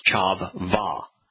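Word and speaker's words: Chavah: There you will find Chava with two V's from Chav and Va, or pronounced Chav Va, a noun proper feminine.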